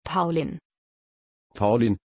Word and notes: paulin: German

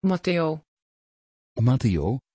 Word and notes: Matéo: Dutch

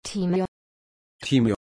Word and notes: timea: German

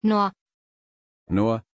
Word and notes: Noor: German

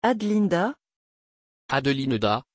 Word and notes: adelinda: French

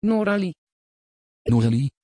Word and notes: Noralie: Dutch

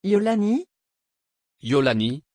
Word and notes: iolani: French

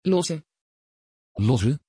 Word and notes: loïse: Dutch

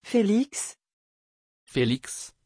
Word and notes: phelix: French

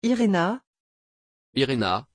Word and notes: irena: French

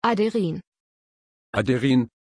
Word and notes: aderyn: German

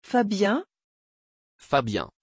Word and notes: fabien: French

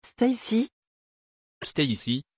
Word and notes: Stacy: French

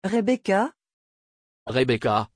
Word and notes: rebeka: French

